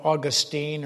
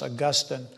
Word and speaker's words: Augustine: You know Augustine, or sometimes it's pronounced Augustine.